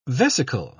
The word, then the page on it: vesicle: British English